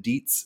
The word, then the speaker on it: Dietz: I'm also available on Twitter at Vitamin Dietz. That's the last name is spelled D-I-E-T-Z, Vitamin Dietz.